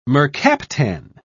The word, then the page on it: mercaptan: British English